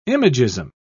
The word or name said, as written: imagism